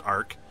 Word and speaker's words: ARQ: It is spelled A-R-Q, but it is pronounced ARK.